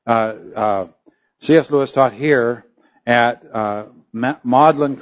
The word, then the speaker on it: Magdalen: It is spelled Magdalen, is the way we would say it, but the British pronounce it Magdalen.